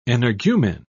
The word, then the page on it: energumen: British English